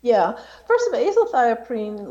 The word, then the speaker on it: azathioprine: Another assault in 1987 was the drug AZT, which is a nucleoside analog, but you also had experience with a drug called, and I'm going to mess up the pronunciation, azathioprine? – Azathioprine.